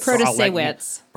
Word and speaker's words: Protasiewicz: Protasiewicz. – Protasiewicz.